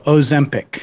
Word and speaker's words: Ozempic: So how's it pronounced again? Ozempic? – I think it's a long O. Ozempic.